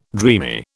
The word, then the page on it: dreamy: British English